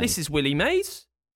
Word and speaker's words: Mays: Willie May. – Right. – No, his name is Willie Mays, but you spell it. – M-A-Y-S.